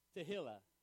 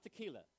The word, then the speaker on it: Tehillah: Tehillah. Not tequila. Tehillah.